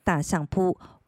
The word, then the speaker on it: 大相撲: 如果是由相扑协会举办的职业比赛，则称为大相扑，大相扑，大相扑。大相扑，这里简单说明一下，相扑念成相扑，但是当相扑的前面多了一个大，这个时候相扑的第一个字“ 斯” 的发音就要变成浊音的“ 字”，所以才会念成大相扑。